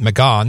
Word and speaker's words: McGahn: On August 19th of 2018, and my calendar is right, that was yesterday, he wrote about Don McGahn. I pronounce it McGahn because I know some German, and it's a German-sounding name.